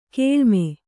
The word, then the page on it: ಕೆಳಮೈ: keḷa mai; kannada